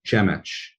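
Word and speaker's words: Przemyśl: So there's a town right across the border, the Medyka border, called Chemech. It's spelled in some bizarre way, P-Z-E-M-Y-L. And Chemech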